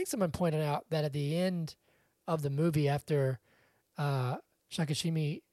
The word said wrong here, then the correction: Shikishima: is that how you say his name? – Shikishima.